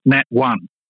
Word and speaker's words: Natone: There's this place called Natone. He pronounced it or got the the lady was going to pronounce it Nat One, but it's actually pronounced Natone.